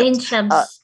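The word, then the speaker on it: Ain Shams: She has a master's degree in environmental media and education from the Ain Sham. You could correct that pronunciation, university in Egypt. – Ain Sham.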